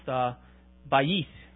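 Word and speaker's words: bayit (בית): Now, in verse 5, the Hebrew word for a house is, now, if I remember correctly, my Hebrew is a little choppy, but it's pronounced bayit.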